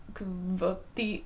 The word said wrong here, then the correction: Kvothe: whatever. – Kvothe.